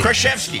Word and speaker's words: Krasinski: Did I pronounce it wrong all the time? – Krasinski. – Krasinski. – Yeah, there you got it.